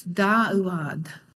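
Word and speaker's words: Da'uad (דוד): Dave pronounced it Dawid. Is that how Dave? I think that's how he's pronounced it. That is how it's usually pronounced in the modern Israeli Hebrew. And you could pronounce it David, or even Dude. I always thought that was kind of funny. In the Paleo, it's Da'uad. Da'uad.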